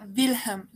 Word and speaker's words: Wilhelm: Y ya no lo quiero decir porque al principio dije William. ¿Y cómo se dice? – Que el nombre de... – del príncipe, ajá. – Se pronuncia Wilhelm, la W es como una V, Wilhelm.